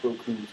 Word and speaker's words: Bochum: It's pronounced Bochum, to my surprise.